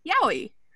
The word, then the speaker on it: yaoi: And the way that works is if you look at the numbers 801 in Japanese, 8 can be pronounced with the counter of yatsu, which if you take the ya from yatsu, it creates the first sound in yaoi. The 0 is actually the Western romanized influence of the pronunciation of 0, which is O. And then we have the E, which represents the 1 in ichi. And that's the I sound at the end of yaoi. So it's yaoi.